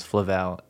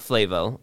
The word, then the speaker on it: Flavel: Flavel, and I feel pretty confident we got it. – It's Flavel, and it's not Flavel or Flavor Flav or something like that. It is Flavel.